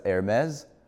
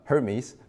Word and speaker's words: Hermes: I'm not gonna pronounce it as Hermes. Right? We're not talking about the French grocery bag company, right? So I'm gonna pronounce it as an English person would, Hermes, right?